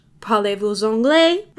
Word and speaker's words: parlez-vous anglais: parlez-vous anglais?